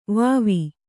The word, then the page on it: ವಾವಿ: vāvi